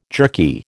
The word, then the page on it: jerky: American English